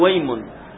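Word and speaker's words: وايم: نقول ويم ويم ويم الله هكذا، ويم الله همزة وصل، هذه همزة وصل فلا تنطق عند الوصل، عندما يتقدم شيء على الكلمة حرف أو كلمة أو أي شيء، إنما عند الابتداء بها فقط تقول أيمن الله، تبتدأ بها مفتوحة.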